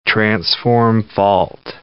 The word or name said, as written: transform fault